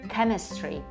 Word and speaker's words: chemistry: Więc weźmy sobie na przykład wyraz chemistry, pisany przez przez H, chemistry, wiecie, to jest chemistry, oczywiście przez K.